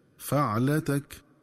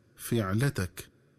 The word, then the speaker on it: فعلتك: ينبغي التنبه للنطق الصحيح للفظ فعلتك، فيقرأ بفتح الفاء هكذا فعلتك، ومن الخطأ قراءته بكسر الفاء هكذا فعلتك.